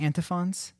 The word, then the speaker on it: antiphons: In church history, there's these things called, how do you pronounce it? Antiphons? – Yeah, antiphons.